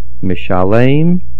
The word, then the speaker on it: Mishalem: Baruch mishalem sachar toiv li re'ov. Mishalem, the stress is on the lamed.